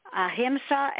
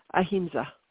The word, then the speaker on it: ahimsa: She says here, can you tell us a little bit about the principle of ahimsa and how it guides you? – Oh, that's a lovely question. So I have that word tattooed on my wrist. – How do you pronounce it? – Ahimsa.